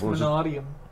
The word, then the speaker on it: Manadium: I've heard that pronounced so many different ways. I'm not sure if I'm pronouncing it the way that it's perceived is correct. – No, it's Manadium. – I really don't want it to be. I've heard it Manadium, Manadium, Manadium. – Yeah, I've heard Manadium. – I don't like Manadium. – Yeah. – It sounds wrong. The emphasis is on the wrong syllable.